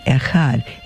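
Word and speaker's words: Echad (אחד): In English, you could spell it E-C-H-O-D, Echad.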